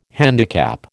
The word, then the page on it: handicap: American English